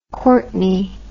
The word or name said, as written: Courtney